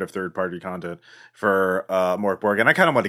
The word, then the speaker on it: MÖRK BORG: And it says it's Mörkborg. – Mörkborg.